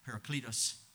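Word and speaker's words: parakletos (παράκλητος): Yes, as counselor and comforter, I don't take away from the Greek parakletos, actually is how it's pronounced.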